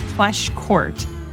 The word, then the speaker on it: Pleschourt: Close, but no, it's Pleschourt.